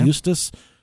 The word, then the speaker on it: Eustis: Eustis, where he would actually meet the woman he would eventually marry.